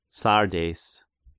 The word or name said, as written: Sardeis